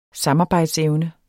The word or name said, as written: samarbejdsevne